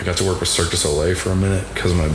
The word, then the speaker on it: Cirque du Soleil: Is that the proper pronunciation? – Yes. – Not instead of what, Cirque de? – Circus Olay. – No, people don't say that.